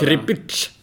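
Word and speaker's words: Kripić: Suleiman Kripic, hur man uttalar Kripic, som Norling sa i en intervju.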